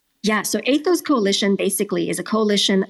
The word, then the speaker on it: Aithos: We say it as Aithos Coalition. It's like the Aithos, ethos of coalition. AI.